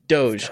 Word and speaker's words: Doge: And did you say that right? That's Dogecoin? – Doge.